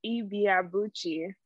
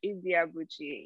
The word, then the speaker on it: Ibiabuchi: So my last name is Ibiabuchi. So Jennifer Ibiabuchi. Yes. Yeah. – Jennifer Ibiabuchi. – Like five syllables. Ibiabuchi. Yeah.